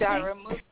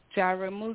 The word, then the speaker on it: Jaramogi: How do you say your last Jaramogi. – Name? – Jaramogi.